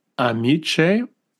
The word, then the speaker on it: amice: In the church is the amis or amis. Am I pronouncing it right? – I would almost pronounce it amice.